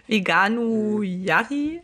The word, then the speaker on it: Veganuari: Veganurari? Kannst du gar nicht aussprechen. Veganuari? – Nee.